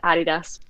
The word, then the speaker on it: Adidas: So, interestingly enough, we had like a major brand strategy reveal this morning, and everyone that's German will say Adidas, and everyone that's American will say Adidas. The correct pronunciation is Adidas because it's from Adi Dassler, which is the founder.